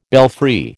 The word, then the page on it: belfry: American English